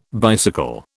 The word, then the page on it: bicycle: British English